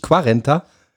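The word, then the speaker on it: cuarenta: Da trinken wir auch Carenta y tres. – Carenta? Heißt das nicht? – Carenta. – Heißt das nicht Quarenta? – Ich kann Qua nicht gut aussprechen, aber sag ich Ka.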